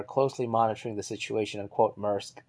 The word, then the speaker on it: Maersk: And again, I don't know how to pronounce that. It's Maersk. I think that's how I've heard it pronounced, I think. It's a Danish company.